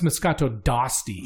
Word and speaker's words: Moscato d'Asti: Because I was saying Moscato d'Asti and they were like, no, it's Moscato d'Asti.